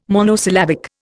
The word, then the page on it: monosyllabic: Indian